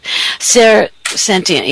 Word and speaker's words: clairsentient: Clairsentient. Yeah, you said it right.